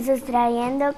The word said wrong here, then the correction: sustraendo: Vale, pero no se dice el sustrayendo, sino el sustraendo, ¿eh?